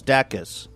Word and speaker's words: Dacus: I think I said her name wrong. – Lucy Dacus? – Is it Dacus? I said her name wrong the first time, I believe. – Dacus. – Your singer-songwriter's new name is Dacus. Dacus. And the song was called VBS.